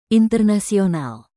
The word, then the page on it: internasional: indonesian